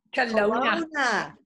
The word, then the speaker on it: Kelowna: Lives in the beautiful vineyards of the Okanagan Valley in Kelowna. Did I say that right? – Kelowna.